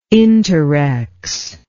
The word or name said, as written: interrex